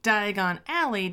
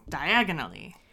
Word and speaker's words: Diagon Alley: The movie just has him put the wrong emphasis on the wrong syllable, so Diagon Alley just becomes diagonally.